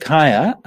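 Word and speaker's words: kāya: Okay, so kaya. Okay, so in the Pali word kaya, K long A Y A is from the root meaning, root from the root chi, as it happens.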